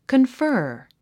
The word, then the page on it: confer: kənfə’ːr; カンファー